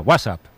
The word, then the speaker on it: WhatsApp: No, peor aún, con acento en la primera. WhatsApp, ¿no? – Un WhatsApp.